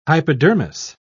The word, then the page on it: hypodermis: British English